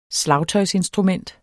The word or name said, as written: slagtøjsinstrument